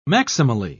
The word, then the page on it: maximally: British English